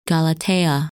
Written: Galatea

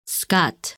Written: Skat

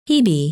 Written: Hebe

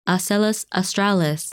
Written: Asellus Australis

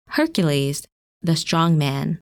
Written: Hercules

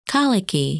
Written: Kalyke

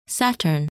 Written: Saturn

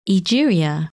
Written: Egeria

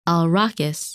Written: Alrakis

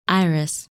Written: Iris

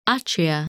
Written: Atria

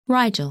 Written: Rigel

